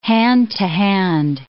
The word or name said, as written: hand-to-hand